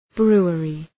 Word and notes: brewery: ‘bru:ərı

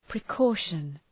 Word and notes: precaution: prı’kɔ:ʃən

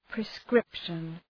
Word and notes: prescription: prı’skri:pʃən

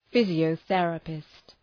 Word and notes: physiotherapist: ,fızıəʋ’ɵerəpıst

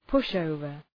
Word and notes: push over: ‘pʋʃ,əʋvər

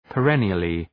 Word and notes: perennially: pə’renıəlı

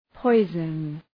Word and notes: poison: ‘pɔızən

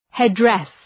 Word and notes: headdress: ‘hed,dres

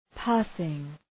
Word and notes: passing: ‘pæsıŋ

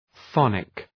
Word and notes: phonic: ‘fɒnık

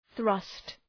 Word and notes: thrust: ɵrʌst

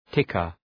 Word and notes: ticker: ‘tıkər

